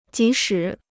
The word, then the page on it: 即使: jí shǐ